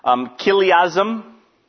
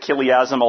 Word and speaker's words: Chiliasm: Chiliasm. That is how you pronounce it. Chiliasm, although it's spelled with a CH.